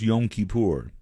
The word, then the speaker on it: Yom Kippur: I believe it is pronounced Yom Kippur.